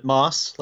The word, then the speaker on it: Maass: Now, I want to say Corey Maass, but I notice it's M-A-A-S. How do you pronounce your surname, Corey? – I generally pronounce it Moss, like the green stuff on a rock.